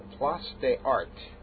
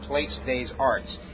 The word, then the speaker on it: Place des Arts: So right now I'm standing outside a station known as, I hope I pronounced this right, Place des Arts. And I know it's not Place des Arts, but I think that's how you pronounce it.